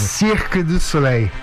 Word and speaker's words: Cirque du Soleil: Por favor, qual que é a pronúncia correta em francês, você que esteve lá durante muito tempo? Fala pertinho do microfone. – Cirque du Soleil.